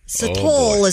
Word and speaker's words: Sithole: Her last name is spelled S-I-T-H-O-L-E. – Okay. – Sitole is how I would say it, but I'm... – Sitole. – S-I-T-H-O-L-E. – That's Sitole, all right. – Sitole. – Oh, so the H is silent. – Maybe it's Sithole. – It's not sit-hole.